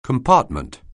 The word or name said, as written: compartment